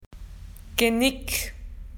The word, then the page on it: neck: Australian English